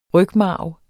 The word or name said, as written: rygmarv